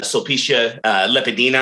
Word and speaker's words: Sulpicia Lepidina: but it was written to Sulpicia Lepidina.